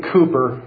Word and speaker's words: Cowper: There's a gentleman, I think I pronounced his name right. It's spelled oddly, but William Cooper, maybe you've heard of him. It's spelled C-O-W-P-E-R.